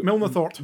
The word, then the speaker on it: Milnathort: Milnathort.